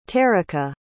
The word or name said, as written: terika